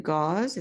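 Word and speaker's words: Gauz': It's a novel that's translated from the French by Frank Nguyen, Standing Heavy, a novel by, is it Gauze? Is that how he pronounces it? – That's how I pronounce it. Yes.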